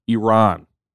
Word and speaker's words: Iran: Iran is how you say it, I guess.